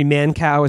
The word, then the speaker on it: Mankiw: And then I found a review of it in the New York Times by the conservative economist Gregory Mankiw, Gregory Mankow is actually how you pronounce that.